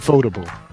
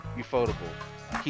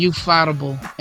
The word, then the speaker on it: ufotable: How you say it? – UFOtable. – UFOtable. He is. – I was calling him you fightable, and I felt like I was like fightable.